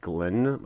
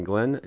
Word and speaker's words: Glenn: My name is Glenn. My first and last name are spelled the same Glenn Glenn, G L E N N, two N's on each.